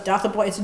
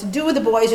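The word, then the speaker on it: Dotheboys: I thought it was Doth the Boys. Do the Boys in the sense of gyp the boys or cheat the boys.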